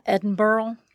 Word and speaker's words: Edinburgh: Edinburgh.